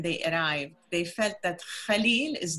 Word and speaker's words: Khalil: And it's Khalil, not Kahlil, and I'll tell you why.